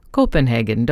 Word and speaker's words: Copenhagen: They say wonderful, wonderful Copenhagen in the Danny Kaye movie, but it's not pronounced like that. It's the people say Copenhagen, don't they?